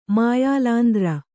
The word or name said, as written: ಮಾಯಾಲಾಂದ್ರ